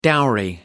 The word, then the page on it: dowry: American English